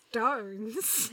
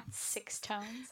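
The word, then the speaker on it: SixTONES: I learned that it's pronounced stones. – Not six tones.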